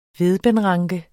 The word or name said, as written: vedbendranke